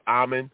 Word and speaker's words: Amon: His name is Amon, like Common, is like Common, is pronounced Amon, Amon Crisden.